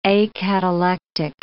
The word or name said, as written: acatalectic